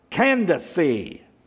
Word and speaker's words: Candace: And there was an Ethiopian, a eunuch, a court official of Candace. The way you pronounce that name, I looked it up in the Greek text. Candace. So if you're named Candace this morning, really, you're Candace. Okay?